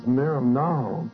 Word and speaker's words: merimnao: The first word is merimnao. It's spelled M-E-R-I-M-N-A-O, and it means stresses, tensions, pressures, and distractions.